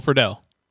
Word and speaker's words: Friedle: And then I always have trouble pronouncing his last name. I know it's Will Friedle. – Yes, Will Friedle. – Oh, that's the first time I got it right.